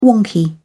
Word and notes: wonky: British English

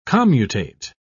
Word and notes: commutate: British English

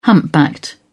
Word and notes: humpbacked: British English

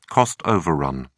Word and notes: cost overrun: British English

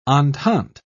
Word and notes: entente: British English

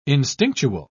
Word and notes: instinctual: British English

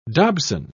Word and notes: dobson: British English

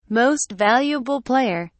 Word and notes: most valuable player: British English

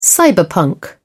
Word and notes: cyberpunk: British English

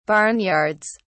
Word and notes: barnyards: British English

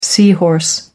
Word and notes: sea horse: British English

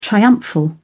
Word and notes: triumphal: British English

